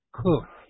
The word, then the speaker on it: Coke: Incidentally, C-O-K-E is the man's last name of who has recorded this maxim, but it's pronounced Cook.